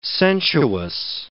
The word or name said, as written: sensuous